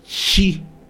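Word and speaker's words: Shi (四): ya que en su pronunciación original es Shi, que también significa muerte.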